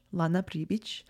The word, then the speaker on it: Pribic: So my name, like if you pronounce it in Bosnian, it's Lana Pribic.